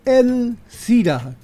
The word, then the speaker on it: Elcira: Y como muy bien lo dice ella, pero lo está pronunciando mal, hoy día celebramos a Santa Elcira con L, porque también hay Erciras con R.